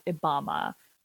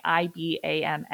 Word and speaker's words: IBAMA: But the key to enforcing these regulations was strengthening a police force that we know of as IBAMA. And that's spelled with an I, so not Barack Obama. although, you know, we also love him. But Obama with an I, IBAMA.